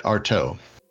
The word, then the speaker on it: Artaud: The D is silent. Excellent. Of course it is, because why not? – Like your big toe. – Excellent. All right. Artaud.